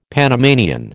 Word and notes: Panamanian: American English

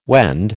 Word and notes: Wend: American English

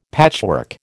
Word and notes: patchwork: American English

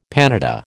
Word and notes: panada: American English

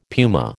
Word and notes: puma: American English

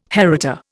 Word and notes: heritor: Indian

